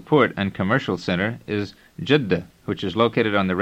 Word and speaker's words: Jeddah: You will find it easy to pronounce this name, but remember to double the D in the middle of the word. First, listen. – Jeddah.